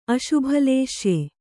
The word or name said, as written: ಅಶುಭಲೇಶ್ಯೆ